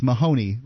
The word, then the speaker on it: Mahoney: That's Mahoney, rhymes with baloney.